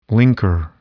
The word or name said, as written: linker